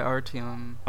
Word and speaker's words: Artyom: Yeah, Artyom.